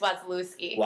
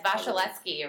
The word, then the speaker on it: Wasielewski: It's Wasielewski. – Wasielewski. – Yeah, it's Wasielewski originally. People change it to Wasielewski because that's how you look at it and pronounce it.